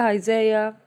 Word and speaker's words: Isaiah: Hosea, Isaiah.